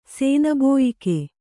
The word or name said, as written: ಸೇನಬೋಯಿಕೆ